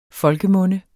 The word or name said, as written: folkemunde